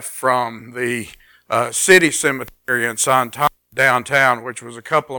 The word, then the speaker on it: Santa Ana: And I remember one time I was down there and I stayed in Santana. Now, you're looking over my shoulder and you're probably saying, well, that says Santa Ana. Well, that's what we would say, but that's the way they pronounce it, Santana.